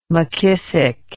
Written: mckissick

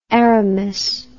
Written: Aramis

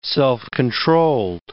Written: self-controlled

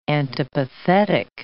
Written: antipathetic

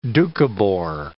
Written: Doukhobor

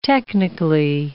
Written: technically

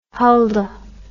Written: Hulda

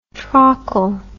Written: trakl

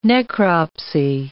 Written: necropsy

